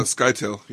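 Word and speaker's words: Scytale: If I'm remembering this right, it's been a few years since I've read it, but it's Skytel, the face dancer. Is that how you pronounce his name? – Skytel, yeah.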